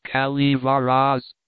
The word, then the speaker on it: Calaveras: The notorious Jumping Frog of Calaveras County, pronounced Calivaras,